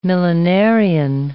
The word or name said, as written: millenarian